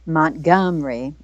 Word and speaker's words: Montgomery: I wasn't in Birmingham, but when I was visiting Alabama, we stopped in Montgomery, or as they say, Montgomery. It's actually three syllables the way they pronounce it in the state of Alabama. We add one up here in the north,